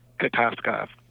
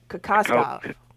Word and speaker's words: Kokoskov: The Phoenix Suns fired Igor Kokoslov? Is that how you say his name? Say it again. – Kokoslov. – Y'all keep saying it at the same time. Go ahead. – Kokoskov. – Kokoskov.